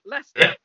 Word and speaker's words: Leicester: when I actually saw you guys and then dug deeper to find out that you were from I'm gonna try and pronounce it Leicester. – good try, Leicester.